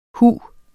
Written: hus

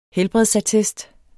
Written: helbredsattest

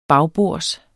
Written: bagbords

